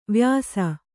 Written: ವಯಸ್ಸು

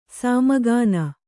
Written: ಸಾಮಗಾನ

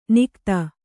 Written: ನಿಖಾತ

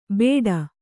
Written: ಬೇಡ

